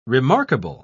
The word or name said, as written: remarkable